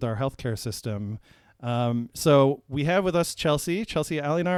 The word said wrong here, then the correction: Aliona: Am I pronouncing your last name right? – Aliona. – Aliona, thank you, yeah.